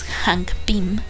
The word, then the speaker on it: Hank Pym: Hank Pym